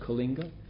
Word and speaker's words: Coalinga: Um, if you take Highway 5 north from LA, you go past Coalinga, C O A L I N G A, Coalinga, right? Pronounced Kalinga.